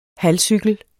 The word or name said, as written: halvcykel